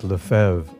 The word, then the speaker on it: LeFevre: Lefebvre, he asked, am I pronouncing it right? – It's actually pronounced Lefebvre, I replied.